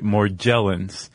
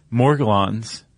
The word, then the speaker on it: Morgellons: I saw on some pronunciation site Morgellons, but we're both going to make a hard G sound.